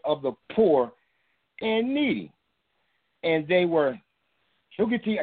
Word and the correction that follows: haughty: I'm trying to pronounce the word right, huggity and haughty, committed, haughty, thank you, and they were haughty and committed, committed abominations before me.